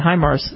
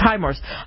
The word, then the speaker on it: HIMARS: If they have the $436 million for these HIMARS, and that's how you say it, HIMARS, it's HIMARS, HIMARS mobile artillery rocket system, that's a Lockheed baby, They have that.